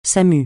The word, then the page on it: Samu: francais